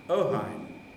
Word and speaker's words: Ohain: The first one, started by a young graduate from Göttingen, called Hans von Ohain, spelled H-A-I-N, pronounced Hein, Ohain.